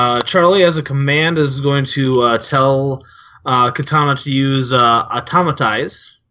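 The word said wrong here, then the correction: Autotomize: No, that's not the way you pronounce it. – Automize, yeah. – Automize, there we go.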